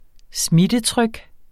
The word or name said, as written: smittetryk